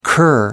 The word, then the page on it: cur: American English